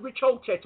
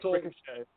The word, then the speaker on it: Ricochet: Ospreay, ain't he that dude that had that wicked piece of wrestling match with Rich Holchett? – Ricochet.